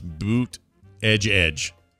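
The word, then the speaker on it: Buttigieg: he thinks it's pronounced boot edge edge.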